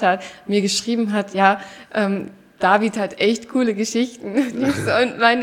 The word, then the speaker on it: David: Genau, du betonst es anders. Ich sage nämlich, ich habe die Betonung auf dem A, glaube ich, nämlich David. Und nicht David.